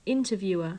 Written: interviewer

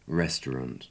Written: restaurant

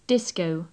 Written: disco

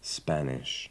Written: spanish

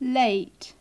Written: late

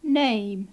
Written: name

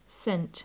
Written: sent

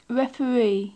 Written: referee

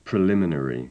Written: preliminary

